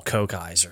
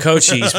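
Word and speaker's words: Cochise: Oh, Kochi's, Kokai's, or however you want to pronounce it. Yeah, well, some people say call Kokai's for some reason. Kokai's. But Kochi's by Audio Slave.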